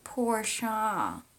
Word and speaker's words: Porsche: What about Porsche? It's actually pronounced Porsche.